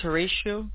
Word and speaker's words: Horacio: My name is Horatio. It's spelled H-O-R-A-C-I-O.